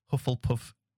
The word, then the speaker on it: Hufflepuff: A Tufflepuff. How do you say that? Say that again. – Hufflepuff. – Hufflepuff. Oh my goodness, that didn't sound at all like Hufflepuff.